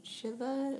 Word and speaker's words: Shiva: Yeah, just, they've talked about this a lot in church, about sitting Shiva, am I saying that right? – That sounds right.